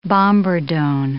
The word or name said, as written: bombardon